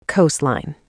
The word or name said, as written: coastline